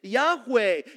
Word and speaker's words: Yahweh: We add the vowels A and E so that we can pronounce it, Yahweh.